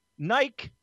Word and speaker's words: Nike: It's official. Nike rhymes with spiky, and you're saying all these.